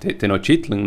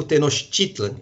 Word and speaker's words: Tenochtitlan: México Tenochtitlan, e não Tenochtitlan, como eu tenho falado. Eu tendo a portuguesar a pronúncia, mas você tem razão na sua pronúncia, o náhuatl não conhece oxítonas, né? Então Tenochtitlan é melhor que Tenochtitlan.